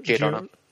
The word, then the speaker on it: Giordano: Moore was going to use them, but managing editor Dick Giordano... Did I say that right? – Yeah, Giordano. – Yeah. – Said it better than I've ever said it.